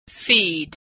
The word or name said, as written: feed